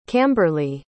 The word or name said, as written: camberley